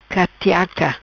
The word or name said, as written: Catiaca